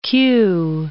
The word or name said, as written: queue